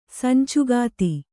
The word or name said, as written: ಸಂಚುಗಾತಿ